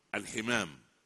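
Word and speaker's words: الحِمام: أضرب مثالا في مدخل المحاضرة، أنت تقول الحمام، يذهب إلى خاطرك فورا طائر الحمام الذي يطير وهو هكذا، وإذا كسرت الحاء وضعت بدلا من فتحة فوق الحاء ناطقا الحمام، اكسر الحاء بكسرة تنطق وتقول الحمام. ما هو الحمام؟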